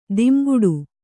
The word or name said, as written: ಧಿಮ್ಮಿಕಿಟ